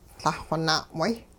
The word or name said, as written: Lah-xw na'way